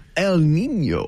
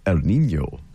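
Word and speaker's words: El Niño: Whenever we talk about El Nino, we have to say it the correct, proper way, which is El Nino.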